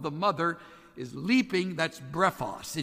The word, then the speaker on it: brephos: It's an interesting Greek word, brephos. Sounds like the word breakfast, B-R-E-P-H-O-S.